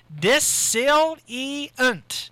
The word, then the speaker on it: dissilient: You know what? I pronounced that wrong. It's dissilient. – Disilient. Disilient. Not dissonant. Disilient.